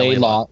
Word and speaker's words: Laidlaw: Starting in January, Mark, I'm going to say his last name wrong, but yeah, Laidlaw.